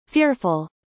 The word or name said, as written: fearful